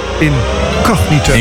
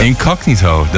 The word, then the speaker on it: Incognito: Incognito, zeg ik dat goed? – Incognito. – Incognito, dat is hem.